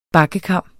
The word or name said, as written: bakkekam